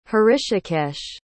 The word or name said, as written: hrishikesh